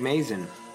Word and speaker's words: Mazin: So I looked up Craig Mazia. Am I saying that right? – Craig Mazin. Mazin. – Oh, that's, I looked like an N.